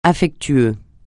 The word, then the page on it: affectueux: French